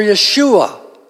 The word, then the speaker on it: Yeshua: Now that name, Jesus, if we go back into the Old Testament, is pronounced Jehoshua or Yeshua. Now in the Greek, it's called Jesus, I-E-S-O-U-S.